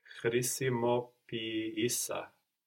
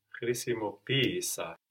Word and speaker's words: χρησιμοποίησα: So we have the accent third from the end, no? But because we are treating these two E's like two separate syllables, the first one takes the accent and we get χρησιμοποίησα.